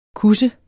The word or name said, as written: kusse